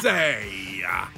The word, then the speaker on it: Caprese: Actually, it's a hard P. – It's pronounced Caprese. – Caprese.